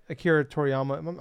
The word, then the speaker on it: Akira Toriyama: Unfortunately, Akira Toriyama, I'm saying that right correctly. – Yes, you are.